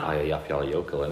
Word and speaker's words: Eyjafjallajökull: Eyjafjallajökull, innit? – That's it.